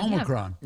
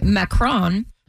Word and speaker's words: Omicron: Omicron. – Yeah. – How do you say it? – Macron.